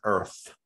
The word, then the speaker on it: Earth: He actually says, welcome to Earth. He pronounces the TH. – Yes. – Everyone says F, like it's like he says.